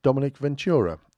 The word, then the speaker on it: Dominique Ventura: You stand there in the queue, looking up, and there's a big picture there of Dominique Ventura. I think that's the way I'm supposed to pronounce it.